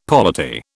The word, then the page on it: polity: British English